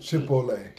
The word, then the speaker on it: Chipotle: What's like, Chipotle? – Chipotle. – Okay. Yeah, my bad. – She didn't put the accent on the wrong syllable.